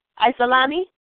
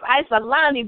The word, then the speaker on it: Icielani: Isolani? – Yes, you got it. You got it right. Thank you. Okay, y'all know who it is. Isolani, baby. I-C-I-E-L-A-N-I.